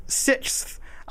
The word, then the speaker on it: sixth: When I say the word sixth, I used to say it like S-I-S-F.